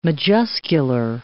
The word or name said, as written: majuscular